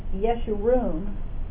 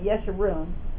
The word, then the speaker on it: Yeshurun: And he chose, my understanding is that this is pronounced Yeshurun, according to the, I don't know, anyway, Yeshurun, with a Y.